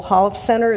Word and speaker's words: Holofcener: I want to put in a vote just for funny women for Nicole Holofcener.